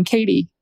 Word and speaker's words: Katy: J-H-A. – And just for the record, everyone, I'm Katie, K-A-T-Y.